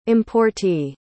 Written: importee

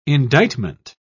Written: indictment

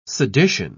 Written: sedition

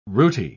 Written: rooty

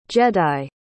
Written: jedi

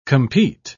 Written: compete

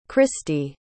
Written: kristi

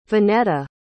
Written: vignetta

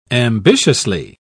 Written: ambitiously

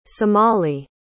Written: somali

